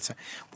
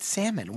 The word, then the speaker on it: salmon: They call it salmon. Why do we call it salmon? Why is the L silent? – I have no idea.